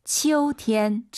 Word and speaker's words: 秋天: 가을, 치우 아니고 秋天. – 방금 우리 청취자분이 게시판에 남겨주셨던 치우가 아니라 아이유 사이에 오 넣어서 치어우. 다시 한번. – 秋天.